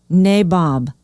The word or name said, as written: Nabob